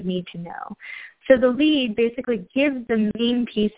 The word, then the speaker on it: lede: The lead, and it is actually spelled that way, I promise I didn't spell it wrong. It's spelled L-E-D-E.